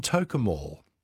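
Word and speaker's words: Tocumwal: Also a silent W in Tokemall.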